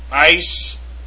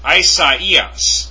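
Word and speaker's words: Isaias (Ἠσαΐας): And here, as in the Greek form for Isaiah, it's pronounced Ice-as. No. Let's go over that again. The Greek form for Isaiah is pronounced Isaias. Isaias. It's actually 4 syllables.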